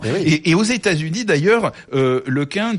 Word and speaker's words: LeCun: Vous voyez, et puis alors il raconte Yann LeCun, il y a un prix Nobel de physique américain qui s'est offusqué que je prononce mon nom en un, car il pensait que c'était impossible en breton.